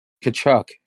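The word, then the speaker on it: Tkachuk: Kachuk.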